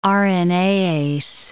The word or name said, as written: RNAase